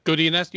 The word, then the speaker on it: Godinez: How do I say her name? I apologize. Yeah, Godinez, you got it.